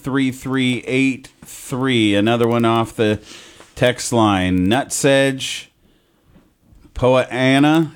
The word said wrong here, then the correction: Poa annua: is that right? Am I saying that right? Poa Anna? – Oh, annual bluegrass, Poa Annua.